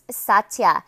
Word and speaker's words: satya: The second yama in Sanskrit is pronounced satya, and in English it means truthfulness.